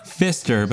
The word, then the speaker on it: Pfister: Pfister, but that's spelled P-F-I-S-T-E-R.